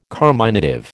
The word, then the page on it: carminative: American English